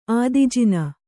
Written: ಆದಿಜಿನ

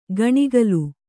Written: ಗಣಿಗಲು

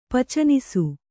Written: ಪಚನಿಸು

